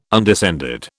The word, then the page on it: undescended: British English